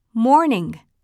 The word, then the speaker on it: morning: Morning Morning 日本語ではモーニングと言うけれど、英語では単語の終わりをもっとソフトに発音するよ。 Morning。